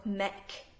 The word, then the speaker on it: Muick: A few weeks ago, John and I were in Aberdeen and we went to Loch Mick. I pronounced it Loch Muick, but it's spelled M-U-I-C-K.